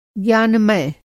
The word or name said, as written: ज्ञानमय